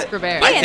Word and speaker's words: Greber: We're going to listen to a song now from friends of yours, Greber. Am I saying it right? – Yeah, Greber.